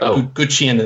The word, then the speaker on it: Guqin: It's pronounced a bunch of different ways, but Gucciën is the way that I've heard it. So Guqin.